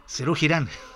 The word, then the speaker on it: Serú Girán: Arrancamos con Serú Girán. Perdón, lo pronuncié como Cirila, Serú Girán, dije. Serú Girán.